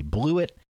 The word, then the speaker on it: Blewitt: The naughty, if you will, the N-O-T-Y. – That's right. Here are your nominations. Chris Blewett, I believe a kicker from Pitt. Chris Blewett, that's spelled B-L-E-W-I-T-T, Blewett.